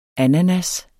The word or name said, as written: ananas